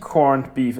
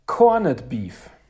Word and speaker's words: Corned Beef: Es heißt nicht Corned Beef, es heißt Corned Beef.